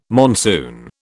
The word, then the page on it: monsoon: British English